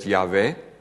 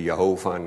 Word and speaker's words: Yahweh: In de inleiding heb ik toen geschreven van ja, eigenlijk geloof ik dat je die naam moet uitspreken met Yahweh. Maar er is verschil van inzicht over. Er zijn mensen die zeggen Jehovah, nou dat heeft niet zo heel veel vaste grond.